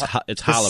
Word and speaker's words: challah: It's C-H-A-L-L. – I know. It's challah. – The C is silent.